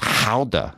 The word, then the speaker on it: Gouda: Actually, I learned that it was pronounced howda is the actual real wow. pronunciation, but if you say it, it just sounds strange. So I'm going to keep of Gouda. Sometimes the mispronunciation becomes more.